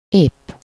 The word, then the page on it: épp: /ˈepː/